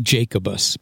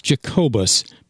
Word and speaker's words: Jacobus: We like to pronounce it Jacobus, but that's not right. It's Jacobus, Pennsylvania.